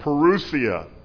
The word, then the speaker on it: parousia: So the word Advent in the New Testament is actually a word called parousia. Parousia. That's how it's pronounced.